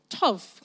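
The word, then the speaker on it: tov (טוב): So I looked up the word goodness in Hebrew, and it's pronounced tov. Can you say that with me? Tov, lovely.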